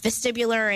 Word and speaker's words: vestibular: So, I did not mean to cut you off before, but you were doing a great discussion about vestibular and how you started to get... am I pronouncing it? Is that the word? – Vestibular. – There you go.